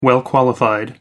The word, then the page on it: well-qualified: American English